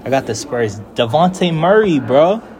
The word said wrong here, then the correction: Dejounte: Oh, DeJounte.